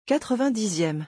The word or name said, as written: 90eme